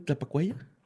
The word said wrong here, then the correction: Tlapacoya: No sé. – Tlapacoya. ¿Tlapacoya? Tlapacoya, Estado de México.